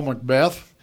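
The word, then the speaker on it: McBeth: The other thing, and you said we start to know their names, Greg, Paul Macbeth.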